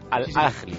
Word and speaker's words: Al Ahly: Al Ahly.